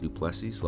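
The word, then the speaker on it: Du Plessis: Yeah, Duplessis.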